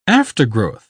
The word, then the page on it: aftergrowth: British English